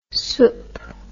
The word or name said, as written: soupe